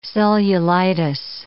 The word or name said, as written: cellulitis